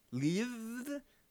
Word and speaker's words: Lviv: Lviv. It's L-V-I-V. I don't know. – Lviv. – You pronounce it somewhat. Lviv. Sure. Lviv. Say it like it's French.